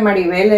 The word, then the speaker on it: Marivel: nunca me decía Maribel, era Mirabel. Nunca me pronunció mi nombre de Maribel, era Mirabel, me decía a la orilla de las tierras, allá por Bacoreguis, donde ella tiene sus tierras actualmente.